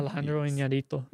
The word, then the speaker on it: Iñárritu: Alejandro Iñárritu.